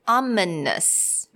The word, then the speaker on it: ominous: So, ominous, right? Three syllables.